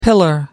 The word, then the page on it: pillar: American English